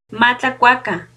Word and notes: ma tlacuacan: Náhuatl

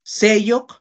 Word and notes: seyok: Náhuatl

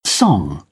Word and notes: song: British English

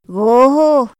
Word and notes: goho: Otomí

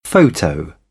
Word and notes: photo: British English; t

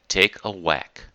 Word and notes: take a whack: idiom

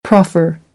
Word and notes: proffer: American English

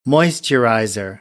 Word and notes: moisturizer: American English

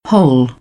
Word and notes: whole: British English; əʊ

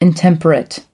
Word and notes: intemperate: American English; ɚ

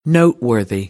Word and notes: noteworthy: American English

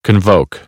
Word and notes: convoke: American English; oʊ

